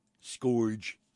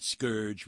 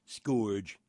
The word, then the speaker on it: scourge: Scourge rhymes with George. Not scourge rhymes with urge. Scourge rhymes with gorge.